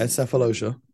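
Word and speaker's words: Sefolosha: You had a guy coming off your bench playing behind Cephalosha? I hope I'm pronouncing this correct. – Yeah, Cephalosha for OKC. – Cephalosha.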